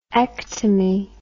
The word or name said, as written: ectomy